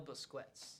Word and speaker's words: Busquets: A player to watch out for Duquesne would be Manel Busquet. I hope I'm saying that right. – Manel Busquets.